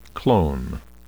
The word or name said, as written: clone